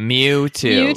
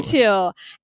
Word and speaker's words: Mewtwo: And so he chooses this Pokemon that's like the most powerful Pokemon, the Mewtwo. Is that how you say it? – Mewtwo. – Mewtwo.